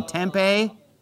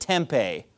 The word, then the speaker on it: Tempe: tempeh. It's Tempe, Arizona. It's not tempeh.